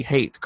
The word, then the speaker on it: HVTE: It goes under, I hope I'm pronouncing this, it's spelled HVTE, but that would be hate, correct? – Yes, sir.